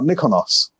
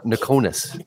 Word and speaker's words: Nikonos: But there is a Greek island called Mykonos. So just change the M for an N and you got Nikonos.